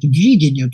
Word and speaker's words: видение: И вот очередное видение, да, я слово подчеркиваю, это ударение на первом слоге, вот видение,